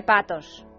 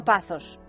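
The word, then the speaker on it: pazzo: Eh, perdona, pero en italiano se dice patos. No pazos.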